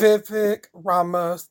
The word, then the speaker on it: Vivek Ramaswamy: Vivek Ramaswamy, if I pronounce that name correctly. – Yeah, that's right.